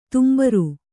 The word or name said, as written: ತುಂಬುರ